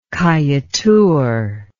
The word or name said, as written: Kaieteur